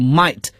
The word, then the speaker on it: mate: Mate, 他说成 mate, 他把 mate 读作 might, 是他们的一种元音变化的结果。